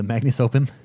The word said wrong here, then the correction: Magnum Opus: I pronounced that completely wrong. – Magnum Opus? – Yeah, that.